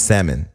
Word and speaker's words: salmon: Look, it's salmon. The L is silent.